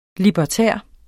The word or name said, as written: libertær